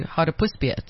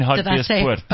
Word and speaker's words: Hartbeespoort: Well, I was going to Hartbeespoort, so it was... – Sorry? Just... You mean Hartbeespoort? – I said it wrong, sorry.